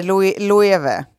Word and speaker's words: Loewe: Ja, det heter Loewe. – Loewe. – Eh, ja, det är spanskt alltså.